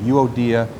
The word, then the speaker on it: Euodia: So I went to the internet and I got the pronunciation. The first one, first lady's name is Euodia, and the second is Syntyche.